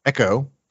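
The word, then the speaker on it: Exo: Now, we've also written about a company called Echo. It's spelled XO.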